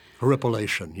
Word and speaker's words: horripilation: Horripilation, yes.